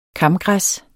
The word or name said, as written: kamgræs